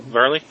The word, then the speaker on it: Varley: How much of this, though, is Lynn Varley? Varney. How do you say his name? – Her? – Her. Yeah, please. – I believe it's Varley, isn't it? – Varley, yeah. So how much of this